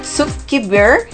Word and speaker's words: Zuckerberg: Zuckerberg.